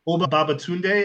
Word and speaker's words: Oba Babatunde: Oba Babatunde as Lamar, the concierge of the hotel that they are staying in later on in the movie.